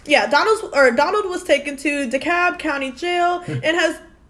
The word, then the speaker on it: DeKalb: Donald was taken to DeKalb. Did I say it right? – DeKalb. – Damn. What the fuck? That is not DeKalb.